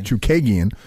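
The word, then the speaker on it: Chookagian: I can never pronounce her last Chukagian. – Name. Chukagian.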